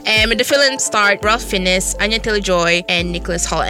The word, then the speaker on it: Fiennes: And in the film, the renowned chef Julian Slowick, who was played by Ralph, I never know how to say his name. Fiennes? – I just call it Fiennes. – Fiennes, yeah. Right, Ralph Fiennes.